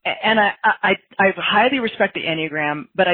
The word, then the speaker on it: Enneagram: Oh, do you mean Enneagram? – Enneagrams, that's it, yeah. – Enneagram, yeah. – I'm saying it wrong, yeah.